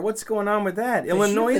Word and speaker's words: Illinois: And I heard a couple people from Illinois lately pronounce the S at the end.